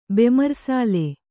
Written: ಬೆಮರ್ಸಾಲೆ

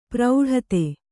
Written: ಪ್ರೌಢತೆ

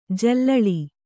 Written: ಜಲಾಲಿ

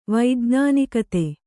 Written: ವೈಜ್ಞಾನಿಕತೆ

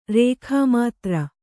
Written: ರೇಖಾಮಾತ್ರ

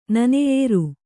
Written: ನನೆಯೇರು

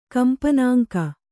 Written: ಕಂಪನಾಂಕ

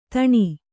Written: ಧೇನು

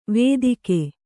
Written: ವೇದಿಕೆ

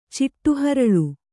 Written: ಚಿಟ್ಟುಹರಳು

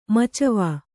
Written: ಮಚ್ವೆ

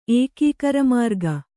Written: ಏಕೀಕರಮಾರ್ಗ

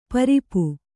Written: ಪೊರೆಪು

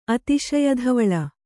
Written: ಅತಿಶಯಧವಳ

